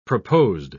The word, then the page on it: proposed: British English